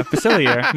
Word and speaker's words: Facilier: Yeah, Facilier.